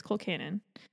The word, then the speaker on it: colcannon: I, I looked it up to, to pronounce before, so that I'm pretty sure it's colcannon.